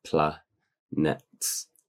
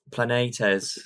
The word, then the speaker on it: Planetes: You sure it's not Planets? – Planetes. And Vision.